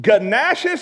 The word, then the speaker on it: gnashes: He foams at the mouth, gnashes his teeth." I believe in pronouncing it properly. Why would you? I've never heard of a silent G in my whole life except for this word. How would you spell Nash? N-A-S-H. No, America. G-N-T.